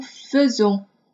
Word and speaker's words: faisons: 那么在这里我们需要提出来的是 faire 和 nous 的变位，它的发音啊是 nous faisons，它发 e 的音，a 一组合在这里发 e 的音啊。